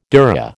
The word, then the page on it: dhurra: American English